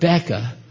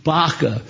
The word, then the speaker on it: Baca: As they passed through the valley of Baca, not Becca, Baca.